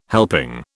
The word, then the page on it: helping: British English